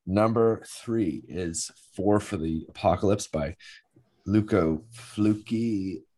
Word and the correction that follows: Fulci: 1975. Uh, are you laughing because I pronounced that wrong? Lucio Fulci. Yeah, Fulci.